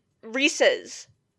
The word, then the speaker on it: Reese's: Since Reese's is pronounced after the man himself, and they're his peanut butter cups, the correct pronunciation is in fact Reese's instead of Reese's.